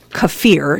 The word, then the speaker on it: kefir: Kefir is how you say that, kefir, cheeses, kimchi, and some other fermented foods.